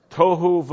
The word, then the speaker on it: tohu: Tohu v'bohu. Can you say that? Tohu vabohu. If you're writing down a transliteration into English, it would be T-O-H-U, tohu. Rhymes with tofu, but it's not. Tohu vabohu. If you're writing down the transliteration, it's V apostrophe B-O-H-U.